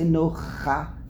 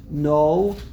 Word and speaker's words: Noach: How do you say Noach? You don't say Nocha. You say, first comes an Aleph. Noach. First is an Aleph, then you end off with the Ches.